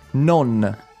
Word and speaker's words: non: non. – Non. There's an N at the end of that. It's not like the English word no.